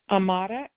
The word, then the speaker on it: Amada: Amada, AMADA, Ramos, RAMOS, Garcia, GARCIA.